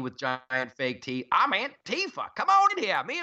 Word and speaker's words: Antifa: I don't want to be on a beach beating up some anti-fa. They need a better name. Can we give them a nickname? – We should have a contest. – It's actually pronounced Antifa.